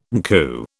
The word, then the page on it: NCU: British English